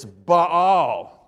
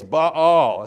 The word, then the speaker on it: Baal: I says, it's Baal, two A's, Baal. That's how you pronounce the Hebrew. You pronounce all the consonants and syllables because they're breath marks down at the bottom. They're vowel marks.